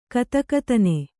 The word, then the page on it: ಗದಗದನೆ: gadagadane